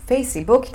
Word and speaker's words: Facebook: Facebook tem quatro sílabas em português, mas em inglês só tem duas.